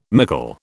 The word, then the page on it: mickle: British English